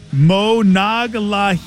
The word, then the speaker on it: Monongahela: N-O-N-G-A. H-E-L-A. If you break it down phonetically, Monongahela, it's kind of easy.